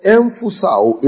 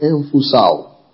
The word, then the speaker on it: emphusao: It's spelled emphusao in the Greek. E-M-P-H-U-S-A-O. E-M-P-H-U-S-A-O.